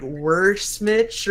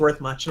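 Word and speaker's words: Wrath Much: I just said like Worse Mitch or something like that, like a sandwich almost. But he's like, no, it's pronounced Worth Much.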